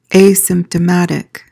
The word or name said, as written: asymptomatic